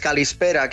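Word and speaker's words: calispera (καλησπέρα): Mira, cuando te digo buenas tardes, calispera, qué sonido más bonito, y es súper fácil de decir, calispera para caló.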